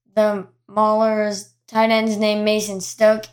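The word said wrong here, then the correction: Stokke: It's Mason Stokey, apparently.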